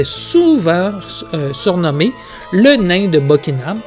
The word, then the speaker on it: Buckingham: Donc, de retour à la cour du duc de Buckingham.